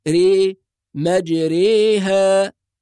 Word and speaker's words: مجريها: الإمالة معناها أن الحرف ينطق بين الفتحة والكسرة، هذا معنى أنه حرف فرعي، يعني بين الفتحة والكسرة. فنقول ري مجريها، هذا هو النطق الصحيح في هذه الكلمة.